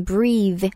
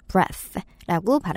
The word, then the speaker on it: breath: s p e l l i n g 은 b-r-e-a-t-h-e, breathe, breathe. 만약에 그냥 숨이라는 명사 형태로 얘기할 때는 어, 발음이 좀 바뀌어요. breath. breath라고 발음을 하는데요.